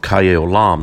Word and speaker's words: Chaye Olam: Then we have the second throne room of Chaye Olam. That's spelled C-H-A-I Olam. And second word, O-L-A-M, is life everlasting.